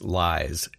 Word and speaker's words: Lyys: New artist that I found, I believe that you pronounce this Lies, L-Y-Y-S. Another one, Lynx, L-L-Y-N-K-S, Western and Maori.